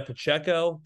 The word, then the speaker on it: Pacheco: Now, Isaiah Pacheco, did I say that correctly? – Yep.